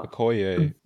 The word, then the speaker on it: Okoye: Okoye? From Black Panther? – Oh, I thought it was Okai. – Sister or the... – Okoye. – Yeah, it's like Okoye, right?